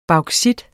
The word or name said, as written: bauxit